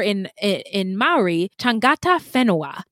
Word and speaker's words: Whenua: Well, I listened to a lot of pronunciations when I went through this, and the lady was saying Whenua, so that's what I put.